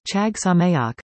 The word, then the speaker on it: Chag Sameach: The appropriate Hebrew greeting during Hanukkah is Chag Sameach, pronounced K-H-A-H-G Sa-M-E-H-Y-A-H-K-H, which means, Happy Holidays.